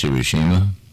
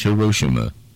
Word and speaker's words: Hiroshima: Somewhere along the line in the last few years, it's no longer pronounced Hiroshima. It's pronounced Hiroshima.